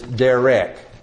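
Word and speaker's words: derek (דרך): And that word is derek.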